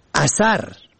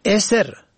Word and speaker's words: اثر: اثر الف سه ر به فتح اول و دوم، نشانه اثر الف سه ر به کسر اول و سکون دوم، عقب آخر به کسر دوم، پسین معنا می دهد.